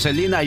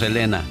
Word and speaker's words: Selena: ¿Por qué algunos la llamamos Selena y otros Selena? – Porque, bueno, en, México pues, le decíamos Selena y en Estados Unidos es Selena. – Exacto, porque en México pronunciamos las cosas como vemos que se escriben.